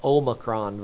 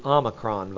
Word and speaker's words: Omicron: By the way, the new virus is not the Omicron virus, it's the Omicron virus. Whenever they, if you hear somebody saying saying that, just so you'll have proper pronunciation of this new virus.